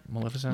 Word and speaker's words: Maleficent: Between the success they've had with Maleficent and... Am I saying that right? Maleficent? – Maleficent. – Maleficent? Maleficent. Sorry.